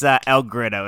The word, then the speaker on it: El Grito: It's El Grito.